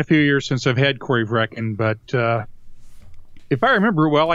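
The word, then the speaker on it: Corryvreckan: Is it Corey Vrekken? – Yeah, Corey Vrekken.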